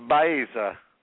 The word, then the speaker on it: Baeza: Baeza. Like John Baez with an A.